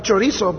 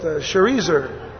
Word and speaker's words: Sharezer: And his brother-in-law, Nergal Sharezer, not Chorizo, but Sharezer. These are hard names, man, but I'm trying to give them to you as best as I can. I think the proper pronunciation is Sharezer, but Nergal Sharezer's reign.